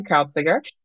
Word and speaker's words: Kreuziger: It's Lisa Baumann Kreuziger, and she's going to tell you in a few minutes how to really say her name.